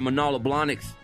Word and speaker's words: Manolo Blahnik: We've also seen Lindsey Johnson win a pair of Manolo Blahniks.